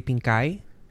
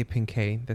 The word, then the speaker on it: Pinkay: Well, there's two ways you could say it. In Spanish, people would read it and say Pinkay. But okay. I guess for English, I just say Pinkay. That's kind of how I grew up.